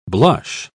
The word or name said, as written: blush